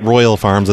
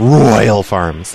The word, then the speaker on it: Royal Farms: Can you do more of an accent on the Roy part? It's not Royal Farms, it's Royal Farms. That's how you're supposed to say it.